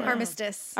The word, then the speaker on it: armistice: Is it Armistice or Armistice? I am not sure. – Armistice. – Armistice? – It's a short eye.